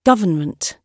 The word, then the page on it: government: British English